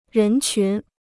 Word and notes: 人群: rén qún

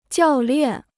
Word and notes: 教练: jiào liàn

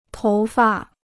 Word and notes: 头发: tóu fa

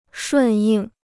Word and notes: 顺应: shùn yìng

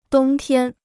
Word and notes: 冬天: dōng tiān

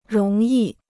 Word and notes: 容易: róng yì

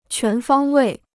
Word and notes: 全方位: quán fāng wèi

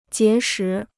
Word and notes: 结识: jié shí

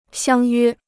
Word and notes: 相约: xiāng yuē

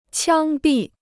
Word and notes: 枪毙: qiāng bì

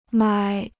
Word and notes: 买: mǎi

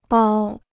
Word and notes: 剥: bāo

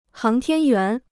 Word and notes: 航天员: háng tiān yuán

